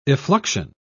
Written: effluxion

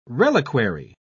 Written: reliquary

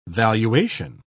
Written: valuation

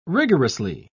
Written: rigorously

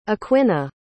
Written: Aquinnah

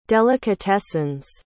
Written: delicatessens

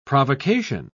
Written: provocation

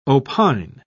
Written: opine

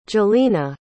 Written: jolina

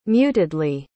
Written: mutedly

